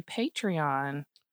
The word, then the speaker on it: Patreon: patreon Patreon Ooh. with a long A.